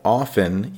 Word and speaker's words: often: Now remember, that's how often, even though it's spelled O-F-T-E-N, often. In America, we tend to say often, and the T is silent.